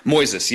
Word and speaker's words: Moises: Am I pronouncing it right? Is it Moises? – Moises, yeah, M-O-I-S-E-S.